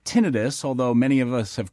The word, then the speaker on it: tinnitus: An estimated 50 million Americans suffer from tinnitus, including many veterans.